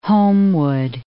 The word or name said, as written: Homewood